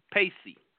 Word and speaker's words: pace: Now, today's word of the day is pacey. Pacey. Pacey is simply a preposition. P-A-C-E. I thought it was pace, but the pronunciation here is P-A-Y-S-E-E. So pacey. Pacey is a preposition, and it means contrary to the opinion of, usually used as an expression of deference to someone's contrary opinion.